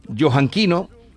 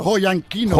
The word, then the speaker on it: Joyanquino: Y ahora presenta hoy los cuentos del profesor Joanquino. ¿No? – Joyanquino.